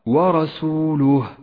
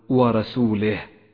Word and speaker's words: ورسوله: وإياك من الكسرة، اقرأ هكذا ورسوله ولا تقل ورسوله فإن ذلك خطأ فاحش.